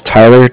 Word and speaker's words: Tyler: My name is Tyler, T-Y-L-E-R, and like I said, I'm calling with Cardiff. It's spelled C-A-R-D-I-F-F, as in Frank.